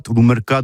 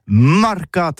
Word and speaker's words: marcat: Eh bien, d'ici à toutis, le mercat de l'Uni ou le mercat des rebelles, mais en gascogne toulousain, c'est prononcé sur les Marcat, avec un A, Marcat.